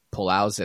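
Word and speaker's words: Palou: Alex Palau, yeah.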